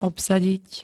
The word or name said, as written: obsadiť